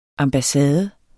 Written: ambassade

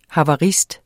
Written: havarist

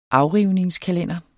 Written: AFRIVNINGSKALENDEREN

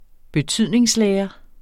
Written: betydningslære

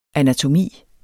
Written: anatomi

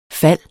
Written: fald

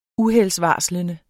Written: UHELDSVARSLENDE

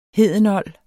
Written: hedenold

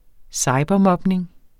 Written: cybermobning

